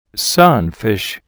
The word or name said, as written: sunfish